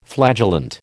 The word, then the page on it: flagellant: American English